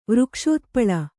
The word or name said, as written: ವೃಕ್ಷೋತ್ಪಳ